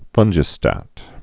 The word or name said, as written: fungistats